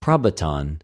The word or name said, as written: προβατον